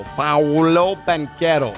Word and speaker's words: Paolo Banchero: Paolo Banchero. – Paolo Banchero. – Mm-hmm. It's an A and O right next to each other, the first syllable.